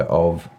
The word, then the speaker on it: AU: You are now the co-founder of AU Consulting, pronounced O, as I've learnt.